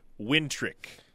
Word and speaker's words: Wnetrzak: It is pronounced Wintrick,